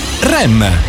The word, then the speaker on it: R.E.M.: Oggi riflettori puntati su REM. I REM, in lingua inglese la pronuncia è R.E.M.,